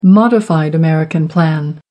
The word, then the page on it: modified american plan: American English